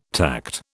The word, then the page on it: tact: British English